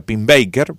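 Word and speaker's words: Pennebaker: Este hombre, su apellido se pronuncia Pim Baker,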